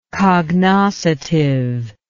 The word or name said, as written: cognoscitive